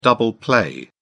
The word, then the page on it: double play: British English